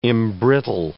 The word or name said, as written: embrittle